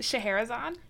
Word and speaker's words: Scheherazade: Isn't it pronounced Scheherazade? – Yes.